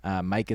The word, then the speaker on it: Myq: Mike is spelled M-Y-Q. Kaplan is K-A-P-L-A-N.